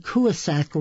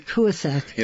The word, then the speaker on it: Coosuk: I've been pronouncing Coosuk wrong. You should have corrected me, Coosuk.